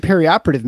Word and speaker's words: perioperative: It's actually perioperative medicine, so uh, anyway, that's that's how you pronounce that.